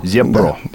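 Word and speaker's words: Zepro: Вы сказали Зипро, это пишется Зепро. как Зепро. Зепро. – Да. Зепро. Ага.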